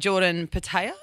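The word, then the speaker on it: Jordan Petaia: He's Jordan Patea. Is that how you pronounce it? – Yes.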